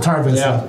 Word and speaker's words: Tar Vizsla: Tar Vizsla? – Tar Vizsla.